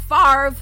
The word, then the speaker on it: Favre: So, Brett Favre, everyone's favorite NFL player who has a goofy-ass last name that does not phonetically match the way it sounds, got duped into making an anti-Semitic video.